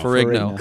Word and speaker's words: Ferrigno: Lou Ferrigno. – Ferrigno.